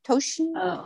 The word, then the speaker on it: Toshin: Trudy, did I pronounce that right? Tosin? Toshin? – Yeah, it would be Shin.